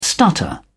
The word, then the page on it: stutter: British English